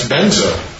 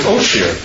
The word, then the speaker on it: Vajra: Vajra in Sanskrit, they pronounce benzo. When it goes from Tibet to Mongolia, the Mongolians pronounce it Oshir.